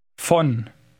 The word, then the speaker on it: von: Von. Von. – Note that German V is pronounced F.